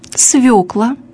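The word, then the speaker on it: свёкла: Для правильной постановки ударения. Свекла, шофер, неровен час, новорожденный, скабрезный, щелочь.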